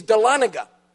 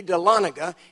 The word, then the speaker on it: Dahlonega: And they began to argue about how you pronounce the name of the town. The one said, it's Dahlonega. The other one said, it can't be Dahlonega. He said, I know a little something about Native American language, and you've got the emphasis on the wrong syllable. It can't be Dahlonega, it's Dahlonega. The guy said, I don't know anything about Native American language, but I'm telling you, this town is called Dahlonega.